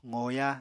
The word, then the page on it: qoya: apatani